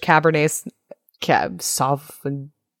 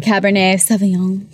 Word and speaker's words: Cabernet Sauvignon: The Cabernet Sauvignon. – Sure. Yes. – That one? – Excellent. Good job. That one. – The red one? – I was mispronouncing it so that you would have a chance to practice.